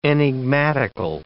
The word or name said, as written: enigmatical